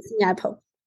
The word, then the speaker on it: MiApple: Yes, MiApple.